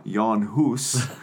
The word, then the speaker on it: Jan Hus: uh, and this is the story of john huss or if we're going to go with the proper pronunciation jan hus um,